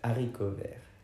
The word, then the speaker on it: haricots verts: Haricots verts. Did I say it right? – Haricots verts. – Haricots verts. – Yeah.